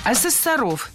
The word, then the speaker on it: ассессоров: Ассессоров – неверно. Правильно – ассессоров. Существительное «ассессор» имеет неподвижное ударение на втором слоге. Ну а раз оно неподвижное, значит, мы его не двигаем при изменении слова. Ассессоров – ассессорами – ассессором.